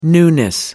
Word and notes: newness: British English

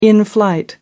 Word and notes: in flight: American English

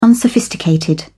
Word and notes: unsophisticated: British English

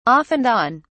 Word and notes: off and on: British English

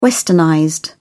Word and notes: westernized: British English

